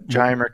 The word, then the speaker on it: Giomer: Yes, gyamer chemistry. That's how I pronounce it. – Yeah, it's spelled G-I-O-M-E-R.